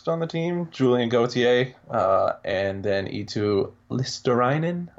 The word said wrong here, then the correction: Luostarinen: Luos- I still can't pronounce it right. – Luostarinen. – Yeah. Luostarinen.